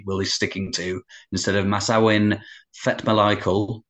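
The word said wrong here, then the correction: Manasawin Phetmalaikul: I hope I've not butchered that. – Sorry, Phil, can you just say, my headphones cut out a bit. Can you just say that last bit again? – Manasawin Fetmalaikul. Better second time round, I thought, actually.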